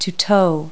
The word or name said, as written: tow